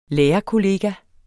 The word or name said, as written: lærerkollega